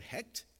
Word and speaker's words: Heqt: But also called Hekt, H-E-Q-T is how it's spelled.